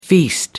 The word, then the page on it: feast: American English